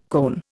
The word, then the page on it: cone: Indian